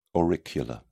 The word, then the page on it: auricular: British English